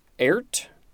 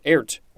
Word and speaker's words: airt: Next we have ERT. I think it's pronounced ERT or ERT. A-I-R-T.